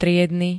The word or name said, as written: triedny